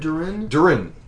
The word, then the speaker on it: Duran Duran: That was Durin Durin? – Durin Durin.